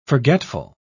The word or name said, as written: forgetful